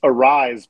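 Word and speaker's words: Arraez: Well, I've seen people complaining about him and, and complaining about the fact um, it's a rise, by the way, according to baseball reference, which is, is usually pretty accurate. And I think I've been overpronouncing the Z too, so I'll, I'll, have to keep that in mind.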